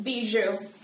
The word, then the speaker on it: Bijoux: Beatrice Bijou. – How do you say that last name? – Bijou.